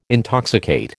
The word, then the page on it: intoxicate: American English